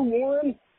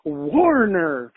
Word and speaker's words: Warner: He kept mispronouncing his name. He kept saying, man, I'll give you a Warren. Hey, Warren. He kept calling him Warren. And, you know, he's sober. So he's just not having this, this young punk that's fucking wasted, you know, mispronouncing his name. And eventually he just, like, got it. He was like, my name is Warner for the last fucking time.